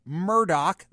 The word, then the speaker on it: Murdaugh: So, for example, at the top, I've got Randolph Murdaugh. That's the correct pronunciation.